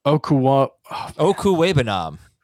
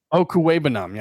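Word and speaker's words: Okwuegbunam: But I think the bigger thing with his return is, and the return of, hold on, I've got the pronunciation, Albert Okuwebenom. – Okuwebenom, yes. Yeah.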